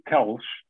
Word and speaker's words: Teles: So my name in Portuguese is pronounced Tiago, and then my surname Teles,